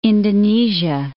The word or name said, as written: Indonesia